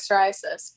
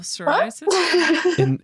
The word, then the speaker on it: psoriasis: I don't have rosacea, I have psoriasis, but it's basically the same thing.